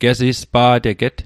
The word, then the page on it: gesispa'teget: Mi'gmaq